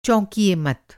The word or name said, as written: चौंकना